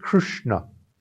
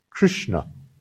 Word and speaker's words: Krishna: So the name of Krishna originally is Krishna, Krishna, Krishna. No, that's how you pronounce it. Three letters have the tongue up, Krishna, Krishna, Krishna.